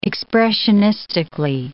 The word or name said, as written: expressionistically